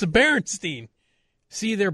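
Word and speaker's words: Berenstain: Watch the Bernstein Bears or whatever they are. – Bernstein Bears? – What are those? – Berenstein. Berenstein. – Oh. Well, I've been saying that wrong Bernstein.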